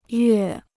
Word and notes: 月: yuè

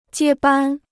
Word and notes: 接班: jiē bān

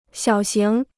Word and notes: 小型: xiǎo xíng

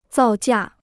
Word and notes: 造价: zào jià

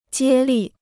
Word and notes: 接力: jiē lì

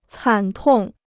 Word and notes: 惨痛: cǎn tòng

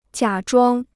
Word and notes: 假装: jiǎ zhuāng

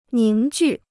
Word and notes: 凝聚: níng jù

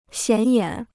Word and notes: 显眼: xiǎn yǎn

